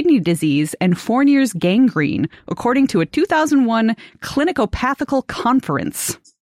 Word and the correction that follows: clinicopathological: Clinicopathological conference. Missed a couple syllables there.